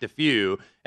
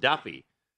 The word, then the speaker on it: Daffue: It's spelled D-A-F-F-U-E. It sounds like DeFew, and that's what I yelled out when Brady and I drafted him at the draft on Tuesday night, and Jeff Davis, of course, Johnny on the spot, he goes, it's actually pronounced Duffy.